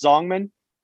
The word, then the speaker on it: Zhongnan: Yeah, it's like, isn't it just Zhongnan? – Zhongnan? – Yeah. – I don't know what I said, but yes, Zhongnan sounds... Now that I'm looking at it, that seems like exactly how you say it.